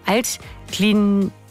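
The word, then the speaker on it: Altglienicke: Die Wölfe müssen beim Regionalligisten VSG Altklinike ran. Habe ich das jetzt richtig ausgesprochen? Ja, Altklinike.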